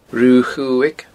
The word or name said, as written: Rubha Chuaig